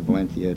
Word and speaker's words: Valencia: Is that the Castilian pronunciation? – Valencia. – Yeah, right. – Yes. – That's good. – Yeah. Sounds like you're lisping.